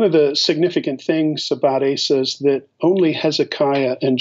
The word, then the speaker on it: Asa: Uh, and so the the next king that we're going to look at is a king by the name of uh, Asa or Asa, if you want to pronounce it uh, in its Hebrew form.